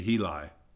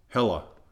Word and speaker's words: Heli: Here it has H-E with the long vowel sound, and then L-I with the long vowel sound and a hyphen between H-E and L-I, so it'd be Heli. But when you, for the first time ever, I got four pronunciations that were exactly the same, and they say Hela.